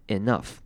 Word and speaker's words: enough: 好，enough 这个字要注意的是，它虽然一样是 e 开头，但是这边跟昨天的某一个字 entire 也是一样，这边的 e 要念成短母音 i 的声音，所以足够这个字是念成 enough。注意它是短母音 i 的声音，enough。